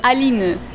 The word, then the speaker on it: Aline: Josie, and how do you say your name? Aline. – Aline. – It's pretty much French.